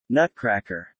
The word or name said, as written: Nutcracker